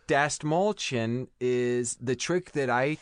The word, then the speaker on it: Dastmalchian: Dastmalchian. That's three syllables. Dastmalchian.